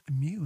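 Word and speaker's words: MØ: It is pronounced Mew.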